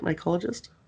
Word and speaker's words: mycologist: And did I say that right, mycologist? – Yes.